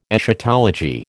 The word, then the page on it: Eschatology: American English